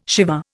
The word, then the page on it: Shiva: Indian